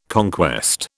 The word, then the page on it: conquest: British English